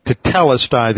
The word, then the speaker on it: Tetelestai: T-E-T-E-L-E-S-T-A-I. Tetelestai. The accent is over the second syllable. Tetelestai. The duplication of this first syllable indicates that this is a perfect tense verb.